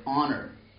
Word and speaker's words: honor: And we still have it in English words like honor, which you don't pronounce, or hour, what, you know, how many hours does it take to for 12 Hare Krishnas to change a light bulb?